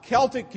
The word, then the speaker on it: Celtic: C-E-L-T-I-C. Now, some of you are going to say, oh, that's Celtics, like Boston Celtics. Okay, but it's still pronounced Celtic.